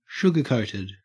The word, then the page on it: sugarcoated: Australian English